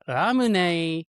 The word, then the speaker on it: Ramune: Yes, it turns out that Ramune is a Japanese pronunciation of lemonade.